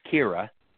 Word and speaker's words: Kyra: It's spelled K-Y-R-A, but it's pronounced Kira, okay? – Right. – But it's like Tyra, but it's Kira. And her last name's Spiwak, S-P-I-W-A-K, Spiwak.